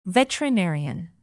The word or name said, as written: veterinarian